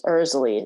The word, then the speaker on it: Erzulie: Erzulie. It's like pronounced like Ursula, Erzulie.